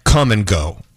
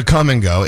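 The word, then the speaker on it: Kum & Go: I know every time we go to Des Moines, we go to Come and Go. It's what we're talking about. It's spelled KUM and Go.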